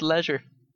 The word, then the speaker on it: leisure: By the way, I like it when it's pronounced leisure. – Well, I go for the British pronunciation.